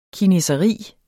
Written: kineseri

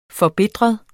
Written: forbitret

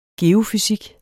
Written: geofysik

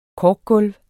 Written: korkgulv

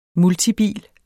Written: multibil

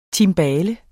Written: timbale

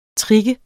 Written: trigge

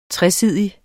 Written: TRESIDIG

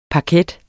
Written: paket

